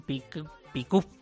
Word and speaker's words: pick-up: Es un pick-up, según mi pronunciación y según la tuya. – Y la del resto del mundo, pick-up.